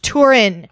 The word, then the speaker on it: Turin: Turin, Italy, Turin. It's pronounced Turin.